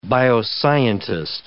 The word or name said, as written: bioscientist